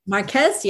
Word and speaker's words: Marquez: So Grace Marquez, I hope I pronounced your name right. – Marquez, yep.